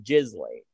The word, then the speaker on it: Ghislaine: No, I honestly believe that it is pronounced. From what I've heard, and I believe it was a CNN anchor that specifically said Ghislaine.